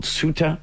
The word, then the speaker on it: Ceuta: Let's go to a place called Ceuta. At least that's how it says it's pronounced in the United States. It is spelled C-E-U-T-A. It's a Spanish territory.